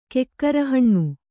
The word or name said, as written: ಕೆಕ್ಕರಹಣ್ಣು